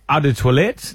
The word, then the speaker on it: Eau de Toilette: Eau de Toilette, that one. – Yeah, that one. – That is the correct pronunciation, no, too.